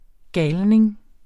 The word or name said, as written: GALNINGENE